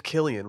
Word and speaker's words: Cillian: His name's Cillian with a hard C. Cillian. You don't see a lot of hard C's.